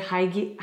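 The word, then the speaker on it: hygge: But this is more like, how do you say that word? – Higgy? – Yes.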